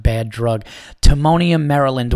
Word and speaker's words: Timonium: So now after this, I go down to Magoobies Comedy Club in, how do you say the town? It's like literally right on the border of Baltimore. It's Timonium. Yeah, Timonium.